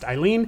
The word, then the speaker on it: Aileen: It looks like it's spelled Aileen, but it's pronounced Eileen.